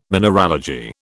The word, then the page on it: mineralogy: British English